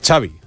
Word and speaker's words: Xavi: Nah, tiene gracia porque lo primero que le pregunta es cómo se pronuncia Xavi Hernández y dice Xavi, like, como chocolate, Xavi Hernández.